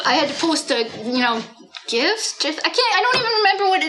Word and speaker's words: GIF: It's a hard G. It's GIF because it says graphical interface format.